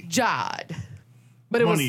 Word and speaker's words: Jade: I knew someone who it was spelled Jade, but they were known as Jod. – What? – Jade, J-A-D-E, but they pronounced it as Jod. – And it makes sense. – But they put an accent over the A, apparently.